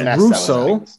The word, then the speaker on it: Russo: I said the Razzo brothers. Yeah. – So it's Russo. Yeah. It's how it's pronounced.